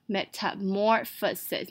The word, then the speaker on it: Metamorphosis: 发音呢， M-E是发短音 A，微笑的嘴型 Met， T-A发短音 A， Met，重音在M-O-R的地方 More， P-H-O发F， Metamorphosis。